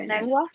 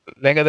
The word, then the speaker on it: lengua: Do y'all like lengua? Try them. The cow tongue. – Yeah, I do. I like cows too. Cows too. – How do you say it again? Oh. I forgot. Lengua. – Lengua de vaca.